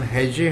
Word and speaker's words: हैजे: इसका उच्चारण हैजे है, हैजा हैजे हैजे, यहाँ हैजे लिखा हुआ है, ठीक है, कॉलेरा हो गए।